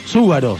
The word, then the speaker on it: Zúgaro: Quiero algo, el tercer programa, y todavía no aclaré que mi apellido es Zúgaro, Z-U, acento en la U, G-A-R-O, ¿no?